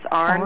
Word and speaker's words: iron: Okay, and then next time you use the word iron, could you pronounce it like my mother does from Southern Ohio? It's Arn.